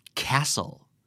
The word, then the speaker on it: castle: ออกเสียงแบบไม่มีตัวทีนะครับ castle